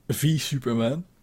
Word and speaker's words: v Superman: En toen kwam in 2016 Batman v Superman. Dus je moet het niet uitspreken als versus, maar V Superman. Er staat geen S.